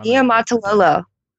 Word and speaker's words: Niumatalolo: Coach Niumatalolo, is that correct? – I have no idea how to pronounce his last name. – Niumatalolo. Niumatalolo. Mm-hmm.